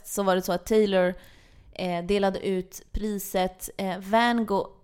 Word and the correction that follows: Vanguard: nu kan jag inte ens uttala det, Vanguard Award, som också kallas för Lifetime Achievement Award, till Kanye West.